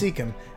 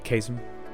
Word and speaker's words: caecum: C-A-E-C-U-M. – It's cecum. I thought it was the opposite. The soft and hard C's were switched in my mind. – Yeah, caecum.